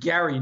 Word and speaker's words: Gerry: It was named after this guy named Eldridge Gerry. Note the hard G there, not the soft G, right?